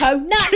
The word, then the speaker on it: Conan: it's Conan the Barbarian. it's a two-syllable word.